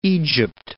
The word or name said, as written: Egypt